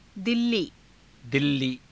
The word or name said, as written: dillii